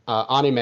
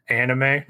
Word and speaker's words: anime: So what is your history with manga and anime and Japanese culture in general? – Well, first of all, you got the pronunciation of manga right, but it's anime, not anime.